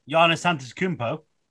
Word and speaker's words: Antetokounmpo: Giannis Antetokounmpo? – Yeah, well done.